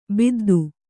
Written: ಬದ್ದಿ

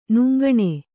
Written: ನುಂಗಣೆ

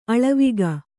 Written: ಆಳ್ವಿಕೆ

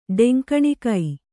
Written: ಡೆಂಕಣಿಕೈ

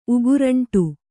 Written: ಉಗುರಂಟು

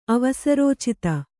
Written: ಅವಸರೋಚಿತ

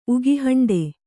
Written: ಉಗಿಹಂಡೆ

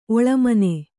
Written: ಒಳಮನೆ

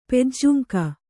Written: ಪೆಜ್ಜುಂಕ

